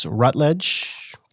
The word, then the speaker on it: Rutledge: And uh, Shawn, I think I think I'm going to butcher this Rutledge. Going to go for that. What do you think? – Yeah, I think you're in the I think ballpark. I think this time you got it right.